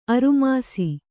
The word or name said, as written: ಅರುಮಾಸಿ